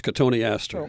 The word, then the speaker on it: Cotoneaster: Spell it Cotton Easter. That's Cotoneaster.